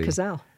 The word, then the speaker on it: Cazale: John Cazale's. Is that how you say it? – John Cazale. – It's a Cazale. – Yeah.